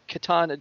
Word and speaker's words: katana: I kept all the way through that, I was trying to tell you that you're saying Katana Jack wrong, and no one was listening to me. I was like, what? No, yeah, it's a katana. That's how you say it.